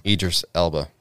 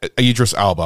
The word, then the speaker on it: Idris Elba: Idris Elba. – Yeah, Idris Elba.